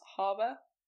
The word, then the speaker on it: Haber: I did try, but also I pronounced Haber wrong, it's actually pronounced Harbour.